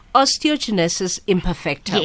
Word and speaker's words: Osteogenesis imperfecta: And then you grow up with what is called tell me if I'm pronouncing it incorrectly, by the way. Osteogenesis imperfecta. – Yes.